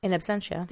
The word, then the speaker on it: in absentia: Under the law, any American fugitive caught in a country where extradition is denied on account of a previous, how do you say it? – In absentia. – There you go.